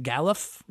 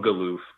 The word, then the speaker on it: Galuf: Is it Galuf? – Yeah. I called him Galuf the whole time.